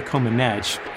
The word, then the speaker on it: Comaneci: At the Summer Olympics in 1976, Romanian gymnast Nadia Comaneci, and by the way, that is how you say it, we fact checked this, her score was perfect.